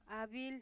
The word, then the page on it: abil: 'abil